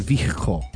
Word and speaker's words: vehicle: I pronounce the H in vehicle.